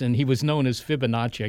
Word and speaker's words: Fibonacci: That's, is that how you pronounce it? – Fibonacci.